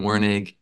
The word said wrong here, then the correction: Werner: and, did I say his name right? – Werner. – Werner.